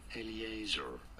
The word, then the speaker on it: Eleazar: There are two different ways of pronouncing it. – Oh, great. – In English, to be fair, it is usually said as Eleazar.